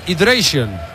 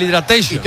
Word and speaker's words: hydration: Es el hydration, no el hidratation.